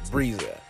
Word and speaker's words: Breeza: I am your host, Breeza.